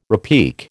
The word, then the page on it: repique: American English